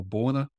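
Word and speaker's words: Baúna: So the focus now, though, is on the, if I got the pronunciation right, the Bauna or Borna fields, B-A-U-N-A, Borna fields in Brazil in the Santos Basin.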